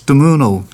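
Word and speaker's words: Modugno: This is Volare, and this is by, I can pronounce this now, it's Domenico Domuno, or Domogno, but to us who can speak a little bit of it, it's Domuno, Volare.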